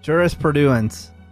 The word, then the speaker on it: jurisprudence: Jurisprudence. – Oh, pretty close. You're adding one syllable.